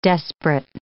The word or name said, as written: desperate